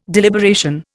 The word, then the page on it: deliberation: Indian